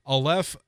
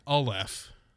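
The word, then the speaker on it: Alef: It's spelled A-L-E-F. I believe it's pronounced Aleph. – Okay. Aleph, okay.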